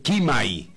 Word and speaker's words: Chimay: Y el tema se llama Chimay, para describirlo tal cual se escribe. C-H-I, Mai. Chimay sería, ¿no?